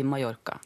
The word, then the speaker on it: Mallorca: og Jeg har vært på Mallorca nylig. Ja, mm. ja Du uttaler det riktig òg. – Ja, men det har, det har strevd veldig mye med, for egentlig sier jeg Mallorca, men så har jeg skjønt da etter masse trøkk på at det er, det er Nå sier vi Mallorca.